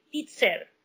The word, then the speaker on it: titser: But a lot of people don't use that word anymore so they always say titser. So they spell it T I T S E R.